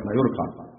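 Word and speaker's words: Mallorca: اعلموا رحمكم الله أن أصلي من مدينة مايوركا أعادها الله للإسلام، وهي مدينة كبيرة، هي تكتب بالجي مايوركا بس تتنطق بالواي مايوركا،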